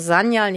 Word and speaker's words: Sanyal: Genau, es ist ein weiches S wie Sonne am Anfang und ansonsten, und es ist die Betonung auf der ersten Silbe, also Sanyal, nicht Sanyal, aber ansonsten ist es alles toll.